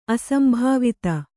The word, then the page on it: ಅಸಂಭಾವಿತ: asambhāvita